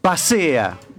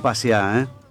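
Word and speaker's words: Paseá: Vos sabés que comenzó ya el movimiento del suelo en lo que va a ser pasea. No pasea, Pasea. – Pasea. – Con acento en la E, pasea.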